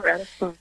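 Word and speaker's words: Ratisbonne: Because we always say pilgrims are different than tourists, but I was amazed by the story that you share of Alphonsus Ratisbon. Is that how you say it? – Yeah, Ratisbon. Mm-hmm. Alphonse Ratisbon. Yep.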